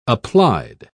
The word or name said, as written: applied